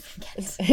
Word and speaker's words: Ghent: Yes, Ghent. Is that how you say it? – Yes.